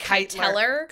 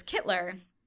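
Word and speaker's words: Kyteler: And that was how I figured out how to spell or pronounce Kittler, because the name is spelled K-Y-T-E-L-E-R. I would have said Kiteller. – Kiteller. – So it's Kittler.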